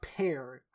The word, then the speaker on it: per: Ankh is a symbol of life, but what does per mean? Or I guess it's pronounced pear. I literally wrote down the word pronunciation guide right next to this. So, and I kept saying per. It's pronounced pear, apparently.